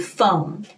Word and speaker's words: foam: 注意一下那个发音是 foam，就是那个泡泡泡沫的意思，foam。